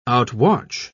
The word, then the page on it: outwatch: British English